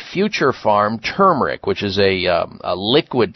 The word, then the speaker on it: Pharm: So make sure if you're using a turmeric, you're using Future Farm liquid turmeric. And farm is spelled P-H-A-R-M.